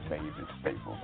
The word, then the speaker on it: Mavis Staples: Let me correct myself. I knew I was saying that woman's name wrong. Her name is Mavis Staples.